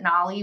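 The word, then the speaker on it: NAILE: Everybody pronounces it different, but I pronounce it Nolly.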